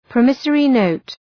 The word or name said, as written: promissory note